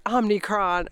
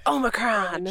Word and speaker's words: Omicron: That's how I knew how to pronounce it, and it was because people were like Omicron. I'm like, it's Omicron.